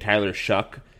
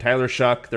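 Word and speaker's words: Tyler Shough: And I think Tyler Shuck, which like, ugh, such a weird way to pronounce that name, but Tyler Shuck, their quarterback, he's going to be really good.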